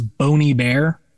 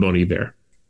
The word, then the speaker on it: Bon Iver: So of course, Bonhiver is the French spelling for good winter. So Bon Iver, so the name means essentially is a version of the French phrase for good winter, which is why I default to the French pronunciation of the phrase and Bon Iver.